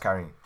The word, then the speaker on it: Karin: It's pronounced Karin. – Oh, it is. – Because the Japanese romanization.